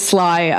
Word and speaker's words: Sly: Cause Leslie, how do you pronounce it if it's just S-L-Y? – It is Leslie, but yeah, my nickname is Sly, That's a weird story that I don't really have an explanation for, but Sly, yeah.